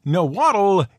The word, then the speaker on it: Nahuatl: Oh, and Nahuatl is a perfectly acceptable English pronunciation for the name of the language itself, in case you were wondering.